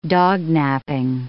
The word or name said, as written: dognaping